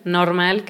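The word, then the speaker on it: NRMAL: Y pues aprovecho también del festival normal, que se escribe como la palabra normal, pero sin la O. N-R-M-A-L. – Perfecto.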